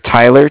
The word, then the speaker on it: Tyler: My name is Tyler, T-Y-L-E-R. I'm calling with Cardiff. It's spelled C-A-R-D-I-F-F, as in Frank Frank.